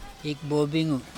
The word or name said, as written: ikbobingu